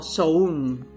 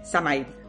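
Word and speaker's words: Samhain: sobre todo con Samaín, que bien pronunciado porque lo he buscado, soy una persona responsable, sería algo así como Soun, ¿vale? Pero bueno, para que me entendáis todos, es Samaín, ¿vale?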